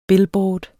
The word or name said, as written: billboard